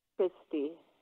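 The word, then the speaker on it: Kristi: This is Christy. I'm sorry. Can you say your name again, honey? – Christy.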